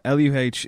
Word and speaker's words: LUH: It's pronounced Luh. – Is it pronounced Luh? – He pronounces it Luh, but then again, he doesn't pronounce anything correctly, so I don't think he can go by, you know.